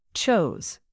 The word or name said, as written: chose